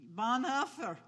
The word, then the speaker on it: Bonhoeffer: Bonhoeffer, yeah.